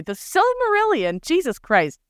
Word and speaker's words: Silmarillion: Because what I have come to learn over these like 5 years of doing this podcast is that the Cimmerillion, am I saying that right? Oh my God. – Silmarillion.